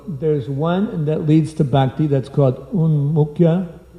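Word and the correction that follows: Bhaktyunmukhi: Let's see, how's it pronounced again? Bhaktyanmukhi sukriti.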